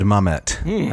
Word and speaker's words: Mamet: This is from David Mamet. I pronounced his name correctly, right? – I believe you did.